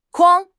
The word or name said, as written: 筐